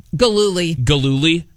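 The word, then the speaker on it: Gillooly: I need you to help me with the last name pronunciation here. Tanya Harding and Jeff... – Galooly. – Thank you.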